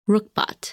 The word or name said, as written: Ruchbah